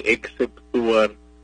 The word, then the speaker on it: exceptuar: Y en el caso de exceptuar, se escribe con XC. Y cuando se pronuncia, pues hay que procurar que se note la X y la C, exceptuar.